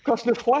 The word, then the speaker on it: Cosnefroid: Voilà, le jeune Cenefroid. J'espère, comme vous, voilà, qui passera. – Qui? – Cosnefroid, il faut prononcer Il est AG2R.